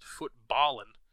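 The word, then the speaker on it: Footbahlin: which I believe is pronounced Footballin, B-A-H-L-I-N.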